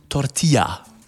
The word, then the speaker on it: Tortilla: Es gibt Tortilla. So, Aussprache war schon mal in Ordnung. Muy bien, sehr gut.